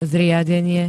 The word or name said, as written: zriadenie